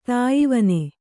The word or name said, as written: ತಾಯ್ವನೆ